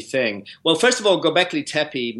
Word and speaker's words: Göbekli Tepe: Did I say that right? Göbekli Tepe? – Göbekli Tepe.